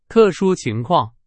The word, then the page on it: 特殊: Chinese